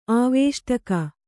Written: ಆವೇಷ್ಟಕ

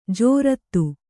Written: ಜೋರತ್ತು

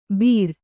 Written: ಬೀರ

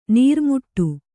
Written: ನೂರುಮಡಿ

